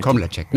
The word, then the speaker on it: Komlacek: Oder wie wird der betont? Komlacek? – Oder? Ja, völlig richtig. Komlacek, ne? Ja, genau. Vorne ja, ist super. die Betonung.